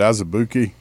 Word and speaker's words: Azubuike: The only chance I think we have is we get is it Azubuike? Is that how you say it? – Yeah.